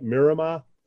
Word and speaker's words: Miramar: Did I finally say that right, Miramar? – Yeah, I think so. you're getting better, I think.